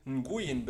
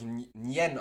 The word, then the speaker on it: Nguyen: Aber wenn ich jetzt Li Fong Nguyen bin, oder das wird ja, glaube ich, Nien ausgesprochen, wenn ich Li Fo Nien bin und mache mir China-Imbiss Shigon Dragon auf.